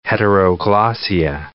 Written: heteroglossia

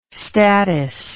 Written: statice

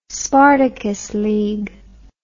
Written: spartacus league

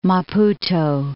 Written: Maputo

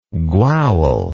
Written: gwawl

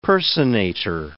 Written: personator

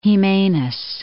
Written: Jimenez

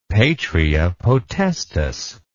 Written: patria potestas

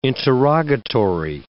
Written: interrogatory

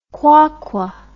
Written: QwaQwa

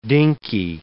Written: dinky

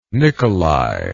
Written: Nicolai

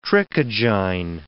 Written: trichogyne

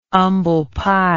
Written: umble pie

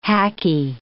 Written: hackie

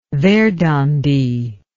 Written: verdandi

